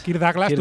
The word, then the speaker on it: Kirk Douglas: Kirk Douglas.